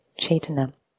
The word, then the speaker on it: cetana: So, cetana, as I mentioned, um, spelled out as C E T A N A. In Pali, the C is pronounced as a ch, Chaitana.